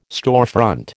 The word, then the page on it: storefront: American English